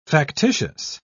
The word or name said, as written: factitious